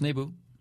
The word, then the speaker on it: Naboo: It's Naboo. – Naboo. – It's Naboo, and it's Dooku.